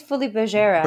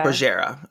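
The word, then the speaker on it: Folies Bergère: After a successful tour of Europe, she broke her contract and returned to France to star in the, and I'm going to say this wrong, Folie Bergère. – Yeah, I think it's Folie Bergère. – Bergère, okay.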